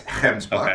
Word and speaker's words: Gemsbok: They didn't have them on the concessions, but he said in the next 2 years they plan on having them, so a Gimsbok definitely on the list. – All right, can I correct you for a minute? It's Gemsbok. Okay. You got to say it right if you're going to hunt it out of South Africa.